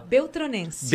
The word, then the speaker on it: Beltronense: Francisco Beltrão, é Beltrãoense? Como é que fala? – Beltronense. – Beltronense, olha aí, né?